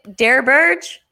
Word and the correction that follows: DeBerg: B, Doug Williams? DeBerg? – Do you mean Steve DeBerg? – DeBerg, yeah, sorry.